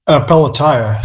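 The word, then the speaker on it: Pelatiah: In Chapter 9, you talk about Pelletier Webster. Did I say that right? – Yeah, Pelletier.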